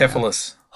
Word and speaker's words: Hoplocephalus: Hoplocephalus.